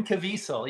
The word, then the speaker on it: Caviezel: He's uh, uh who Diesel is that how you say his name? Vin Diesel. Jim, the guy that is in the movie. – Oh, Jim, oh, Jim Caviezel, yeah. Caviezel.